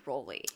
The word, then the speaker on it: Raleigh: she would talk about him, she said Rolly. – Okay, well. – So I think Raleigh. I think we were saying it wrong.